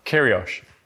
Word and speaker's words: Kyrgios: Maybe you heard that Nick Kyrgios, is that how you pronounce his name? Looking at Luigi. Kyrgios. Great.